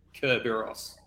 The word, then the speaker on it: Kerberos: It's got a K. When it's got the annunciation, it does have Kerberos.